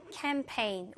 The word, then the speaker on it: campaign: 두 번째 빈칸은 campaign. 우리가 campaign은 좀 익숙한 단어긴 한데요. 영어로 쓸 때는 사실은 이렇게 쓴다는 거. 그러니까 G가 묵음이지만 캠페인에서 스펠링에서 살아난다는 거꼭 기억해 주시고 Our club is holding a wildlife campaign tomorrow.